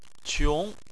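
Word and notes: qiong: tone 2

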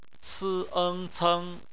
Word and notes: ceng: bpmf

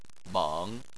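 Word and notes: meng3: tone 3

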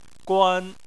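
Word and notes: guan: tone 1

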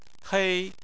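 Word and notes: hei: tone 1

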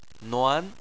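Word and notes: nuan: tone 2

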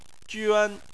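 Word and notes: juan: tone 1

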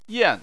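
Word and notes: yan: tone 4

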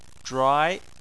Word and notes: zhuai: 2

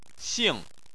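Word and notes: xing: xing4; tone 4

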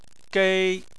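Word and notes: gei: tone 1

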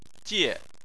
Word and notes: jie: 4

